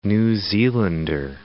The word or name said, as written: New Zealander